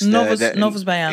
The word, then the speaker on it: Novos Baianos: Novamos Baianos. – Novos Baianos.